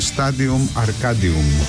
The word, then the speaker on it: Stadium Arcadium: Ο τίτλος ήταν Stadium Arcadium. Ακολούθησα τη λατινική προφορά, είμαι επηρεασμένος από τα πριν, από την Καρχιδόνα.